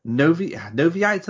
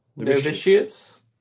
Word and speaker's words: Novitiates: they've got the Noviites, I think it's pronounced. – Novitiates.